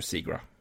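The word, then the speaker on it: Zegras: Eller ja. Segras, da, om du vil. Men jeg tror det uttales Trevor Zegra.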